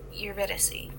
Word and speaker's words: Eurydice: Um, uh, how do you pronounce his um um Eurydice? Eurydice. – Okay.